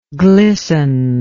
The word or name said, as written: glisteningly